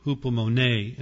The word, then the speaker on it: hupomone: That's actually hupomone in pronunciation. H-U-P-O-M-O-N-E, hupomone.